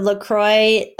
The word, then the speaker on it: La Croix: LaCroix is the perfect French pronunciation of the word, but it is a Wisconsin brand based off of the LaCroix River.